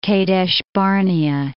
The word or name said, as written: Kadesh-barnea